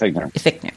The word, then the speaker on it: Fichtner: Is it Fichtner? Is that how you say it? – Fichtner.